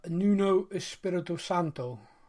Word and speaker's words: Nuno Espirito Santo: As it is, we do have a new manager, Nuno Espirito Santo. Is that how you say his name? – Oh, I think you've done a pretty good job at that.